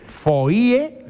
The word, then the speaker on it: foie: Foie, que es una palabra francesa que a mí siempre me ha llamado mucho la atención porque se escribe foie, ¿eh? Y se pronuncia foie. Fíjate, la O, la I y la E se escribe y luego se pronuncia con la U.